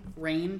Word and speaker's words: Rayne: name, Rain, pronounced Rain. It's spelled... – It's spelled R-A-Y-N-E.